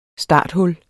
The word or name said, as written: STARTHULLER